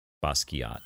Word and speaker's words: Basquiat: Basquiat. – Okay. – Basquiat? – Basquiat.